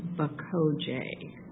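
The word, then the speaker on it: Baxoje: It's B-A-X-O-J-E. – Okay. – And I believe it's pronounced Bokoje. – Okay, so we basically have six right now. Meskwaki, Iowa, Salk, Hinage, Bokoje, and Nequa.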